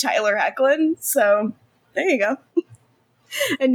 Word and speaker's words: Hoechlin: Tyler Hoechlin of Palm Springs and having a good butt fame.